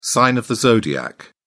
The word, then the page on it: sign of the zodiac: British English